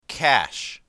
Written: cache

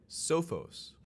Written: Sophos

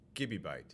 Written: gibibyte

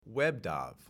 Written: WebDAV

